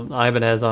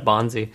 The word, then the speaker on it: Ibanez: he called it Ibonzi. – Ibonzi. I thought you were gonna say Ibanez, because that's how I've always heard it pronounced, like as a person's name.